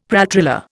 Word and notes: pralltriller: Indian

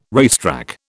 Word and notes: racetrack: British English

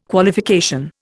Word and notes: Qualification: Indian

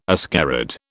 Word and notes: ascarid: British English

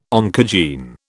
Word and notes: oncogene: British English

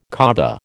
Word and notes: Kaaba: American English

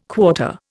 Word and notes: quarter: Indian

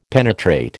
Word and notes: penetrate: American English

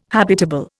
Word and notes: habitable: Indian

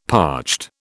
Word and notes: parched: British English

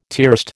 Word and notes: tierced: American English

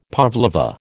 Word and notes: pavlova: American English

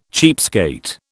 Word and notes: cheapskate: British English

